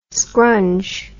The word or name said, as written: scrunge